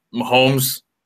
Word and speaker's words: Mahomes: Mahomes, that's how you pronounce the last name, out of Texas.